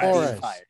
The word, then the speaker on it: Flores: Any thoughts of Brian Flores? Flower, I can't pronounce words. – Flores. Flores. – Flores. – Flores. Thank you.